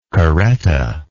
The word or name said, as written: carreta